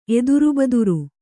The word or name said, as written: ಎದುರುಬದುರು